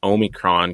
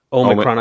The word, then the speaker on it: Omicron: I had to look up how it's pronounced, and apparently it can be pronounced several different ways. Omicron. I thought it was Omicron. Yeah, yeah. Apparently, uh, Marissa said that President Biden said Omicron, so then other people started saying it, and I was saying I was saying yeah.